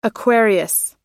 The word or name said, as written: aquarius